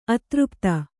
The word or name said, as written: ಅತೃಪ್ತ